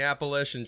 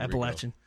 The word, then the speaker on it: Appalachian: Going through Florida, up the Carolinas, crossing the Appalachian Trail, going into... Did I say that wrong? – Yes. – Oh, boy. – Here Appalachian. – There you go.